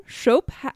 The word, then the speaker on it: Shope: Shope. I'm assuming it's pronounced Shope because it's S-H-O-P-E.